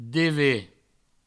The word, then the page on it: dever: deve; Provençal